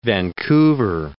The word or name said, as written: Vancouver